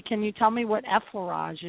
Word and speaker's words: effleurage: And if I mispronounce this, you'll have to correct me, but the effleurage technique made easy.